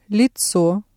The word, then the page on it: лицо: Russian